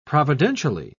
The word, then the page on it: providentially: British English